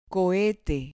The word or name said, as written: cohete